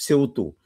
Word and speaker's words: Сеуту: В Сеуту. Ударение, как мне объяснили, на последний срок.